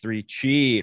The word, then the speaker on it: 3Chi: Pronounced 3Chi. It rhymes.